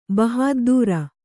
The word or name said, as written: ಬಹಾದ್ದೂರ್